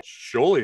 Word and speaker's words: Sjolie: Sjolie, yeah, that is correct. It's a unique, Sjolie, different last name.